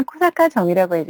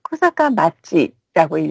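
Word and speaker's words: 쿠사카 마치라고 (小坂町): 이것도 또 우연하게도 이 무라키님은 지난주 하나카페에서 관광정보로 소개를 해드렸던 이 쿠사카 철도 레일파크가 있는 아키타현 쿠사카 정, 조금 전에 편지에는 쿠사카 정이라고 해주셨는데요. 실제로 이 정은 쿠사카 마치라고 읽어요.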